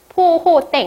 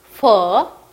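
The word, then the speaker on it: phở: 하지만 요거는 베트남어로 잘못 읽은 거예요. 자, 우리는 이제 베트남어 발음을 배우셨으니까 어떻게 읽어야 돼요? 퍼 라고 읽어야 되죠. 퍼, 포 하면은 쌀국수 아니에요. 퍼 라고 읽으셔야 됩니다.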